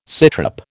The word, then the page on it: sitrep: American English